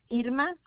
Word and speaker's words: Irma: My first name is Irma, I-R-M-A. But you might have me only by Nelly, N-E-L-L-I-E, Galvan.